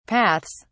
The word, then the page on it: paths: British English